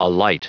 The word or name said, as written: alight